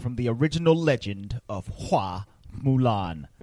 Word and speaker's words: Hua Mulan: I think her name in Chinese, like in the from the original tale, is pronounced Hua Mulan. – Fa Mulan.